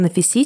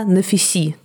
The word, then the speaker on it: Нафиси: В русской Википедии написано «Нафиси», там проставлено ударение. Я послушала даже иранские радиопередачи, в которых тоже звучало «Нафиси», но мне показалось, что там какое-то более плавающее ударение, а потом я посмотрела англоязычные видео в Ютубе, и там все говорят на фисе, но я буду говорить на фиси.